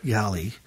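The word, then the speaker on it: Galley (Галлей): Vega, by the way, it stands for Venus Halley, and there's no H in Russian. – So oh, yeah, right. – It's pronounced as Galley.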